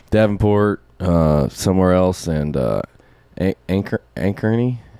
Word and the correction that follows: Ankeny: Anchor Ankeny? – Ankeny.